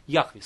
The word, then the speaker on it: Яхве: Они его произносят как Иегова. Это неправильное, ошибочное произнесение этого имени. Настоящее имя Бога – это Яхве, сущий.